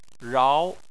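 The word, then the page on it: rao: tone 2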